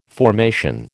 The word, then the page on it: formation: American English